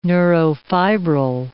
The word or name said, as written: neurofibril